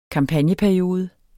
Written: kampagneperiode

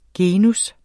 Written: genus

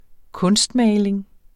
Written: kunstmaling

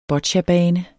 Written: bocciabane

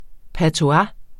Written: patois

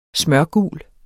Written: smørgul

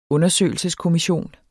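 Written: undersøgelseskommission

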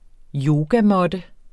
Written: yogamåtte

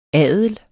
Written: adel

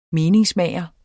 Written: meningsmager

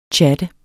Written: chat

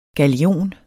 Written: galion

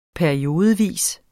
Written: periodevis

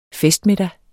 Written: festmiddag

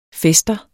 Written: fæster

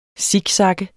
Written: siksak

